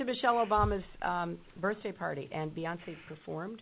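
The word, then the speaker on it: Beyoncé: Beyonce, right? – Yes. – Yeah. You know what? I was really tired that day. What can I say? – It's Beyonce, though.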